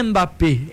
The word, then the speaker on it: Mbappé: Não é Mbappé, é Mbappé. Então o M é mudo nesse sentido.